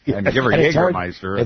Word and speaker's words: Jägermeister: And you know, Jägermeister, it's the soft J.